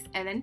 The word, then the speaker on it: Helene: My name is Helene Parker, formerly Fernandez, as of October 2018. It's pronounced Ellen without the H because my first language is French, but it's spelled H E L E N E.